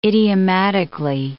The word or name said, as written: idiomatically